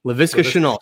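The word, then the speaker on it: Laviska Shenault: They bring in, I can't even pronounce his name, the wide receiver there, Chenault. Yeah. Is that how you pronounce it correctly? LaVisca Chenault, correct.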